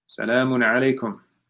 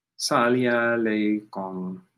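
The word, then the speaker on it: As-salamu alaykum: Salaamu Alaikum. Alaikum Assalam. Wa Rahmatullahi Wa Barakatuh. Chinese Muslims can't say it properly. They say, Sali alaykum.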